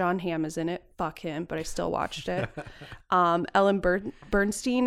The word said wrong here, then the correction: Burstyn: is in it. I cannot say her name. – Burstyn. – Thank you. – Ellen Burstyn. – Thank you. – Ellen Bernstein. – I cannot say it. The Y throws me off.